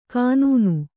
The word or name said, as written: ಕನ್ನ